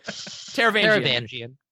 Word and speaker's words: Taravangian: Taravangian.